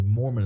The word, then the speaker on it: Moorman: Now, this is not a Mormon, but his last name is spelled M-O-O-R-M-A-N. So Mormon is how you pronounce it.